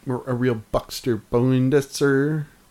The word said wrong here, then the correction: Buster Poindexter: What? – And one more again. – I'm the one who mispronounces things. – A real Buster Poindexter. – There we go.